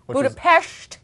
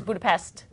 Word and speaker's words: Budapest: Budapest, apparently the correct pronunciation. – Yep. – Yeah. I always thought it was Budapest.